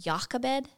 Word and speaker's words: Jochebed: Her name, it can be pronounced Jochebed. – Okay. – And it's the same beginning pronunciation of Yahweh.